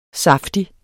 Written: saftig

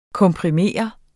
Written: komprimere

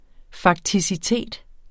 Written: fakticitet

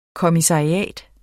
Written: kommissariat

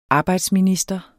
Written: arbejdsminister

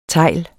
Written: TEGLEN

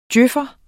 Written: DJØF'er

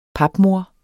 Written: papmor

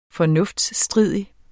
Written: FORNUFTSTRIDIG

